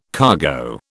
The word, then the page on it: cargo: British English